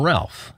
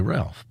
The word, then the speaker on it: Ralph: Vaughan Williams always pronounced his first name. It's written Ralph, so in America we would say Ralph, but he always pronounced his first name Ralph.